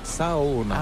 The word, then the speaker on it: Saona: Et comment on dit Sona? – En fait, on prononce chaque lettre, donc c'est Saona.